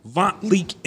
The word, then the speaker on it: Vont Leak: Vont Leak in the building.